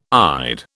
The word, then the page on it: ide: British English